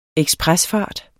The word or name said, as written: ekspresfart